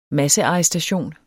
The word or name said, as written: massearrestation